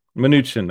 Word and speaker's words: Mnuchin: Steve Munchen was also, I can't pronounce his name, Munchen. So the guy that was Donald Trump's right-hand man, I think. – Mnuchin, there we go.